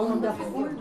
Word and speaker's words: wonderful: L'accent wonderful. Sur la première, c'est pas wonderful, mais wonderful.